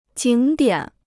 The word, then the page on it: 景点: jǐng diǎn